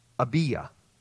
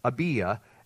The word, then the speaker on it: Abia: It says here that he was of the division of Abia. That's the way that probably should be pronounced.